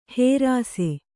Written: ಹೇರಾಸೆ

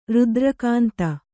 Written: ರುದ್ರಕಾಂತ

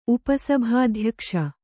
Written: ಉಪಸಭಾಧ್ಯಕ್ಷ

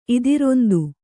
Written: ಇದಿರೊಂದು

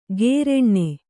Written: ಗೇರೆಣ್ಣೆ

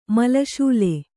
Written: ಮಲ್ಲಶಾಲೆ

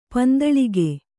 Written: ಪಂದಳಿಗೆ

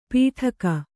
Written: ಪಿಟಕ

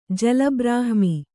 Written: ಜಲಬ್ರಾಹ್ಮಿ

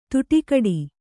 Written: ತುಟಿಕಡಿ

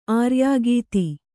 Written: ಆರ್ಯೋಕ್ತಿ

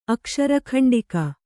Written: ಅಕ್ಷರಖಂಡಿಕ

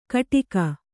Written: ಕಿಡಿಕೆ

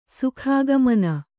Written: ಸುಖಾಗಮನ

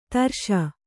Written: ಧೀರೈಸು

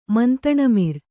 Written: ಮಂತಣಮಿರ್

